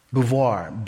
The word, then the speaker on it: Beauvoir: Simone, S-I-M-O-N-E, de, D-E, Beauvoir, B-E-A-U-V-O-I-R. Let me do that one more time. Simone, S-I-M-O-N-E, D-E, and then the last name is B-E-A-U-V-O-I-R.